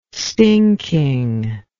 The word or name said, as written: stinking